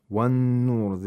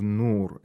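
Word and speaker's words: النور: وَالنُّورُ, the noon of nur is pronounced with shatta and ghunna, because noon is a sun letter and makes the noon mushaddad.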